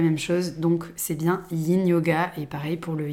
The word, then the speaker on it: yin: Le yin yoga, est d'ailleurs, petite parenthèse, euh, on ne dit pas ying, mais bien yin, ça s'écrit Y-I-N, euh, c'est un des fondements euh, de la médecine traditionnelle chinoise, et je vous le confirme, il n'y a jamais eu de G,